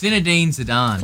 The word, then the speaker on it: Zinedine Zidane: It's Zinedine Zidane.